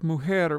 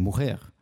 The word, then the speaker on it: mujer: And the other thing is that, so in that case, that's a really great example, because the J in Spanish today is not pronounced J like in Portuguese. You know, you would say mujer. But in Ladino, it's mujer, the same way that Portuguese would pronounce it, or maybe French.